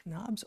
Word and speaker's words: NOBS: It's right there in the name, N-O-B-S, Knobs. You can pronounce it either way you like, Knobs or no BS.